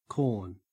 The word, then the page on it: corn: Australian English